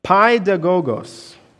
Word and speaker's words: paidagogos: And your version might say something a little different, but this word guardians, trustees, that is coming from a Greek word that is pronounced paidagogos.